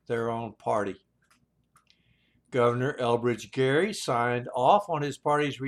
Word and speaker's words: Gerry: They quit calling him Gerry and started pronouncing it Jerry, and the word gerrymander was added to the English language.